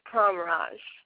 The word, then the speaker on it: Pramraj: my whole name is Sally Tsertsvadze Pramraj.